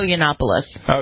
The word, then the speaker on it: Yiannopoulos: Is it Yiannopoulos? How do you pronounce it? Yiannopoulos? – Milo Yiannopoulos.